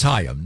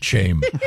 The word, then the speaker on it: Chaim: It's Hayim, not Chaim.